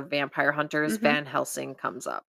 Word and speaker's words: Van Helsing: Van Helsing.